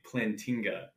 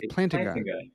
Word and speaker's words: Plantinga: Never ever say Plantinga. That is the worst pronunciation.